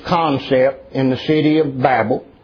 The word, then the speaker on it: Babel: And our study Wednesday night in Genesis, we looked at the city and tower of Babel, or Babel as it's supposed to be pronounced.